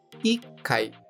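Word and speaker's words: 一階: Com atenção, pois apesar do número 1 um ser, de modo geral, Ichi, neste caso específico, a sílaba Ti é retirada e colocado o Ti tsu que é aquele Tsu pequeno, indicando uma pausa rápida antes do Kai, ficando Ikai.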